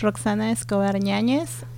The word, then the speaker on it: Roxana Escobar Ñañez: And I'm pronouncing it in English, but let's see what Roxana has to say. Hello. – Hello, hi, my name is Roxana Escobar Ñañez, I'm from Peru.